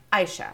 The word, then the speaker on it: Aisha: I'm like, Aisha. And they're like, great, Aisha. And I was like, no, just Aisha, like an eyeball.